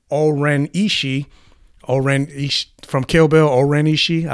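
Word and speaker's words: O-Ren Ishii: Oren Ishii.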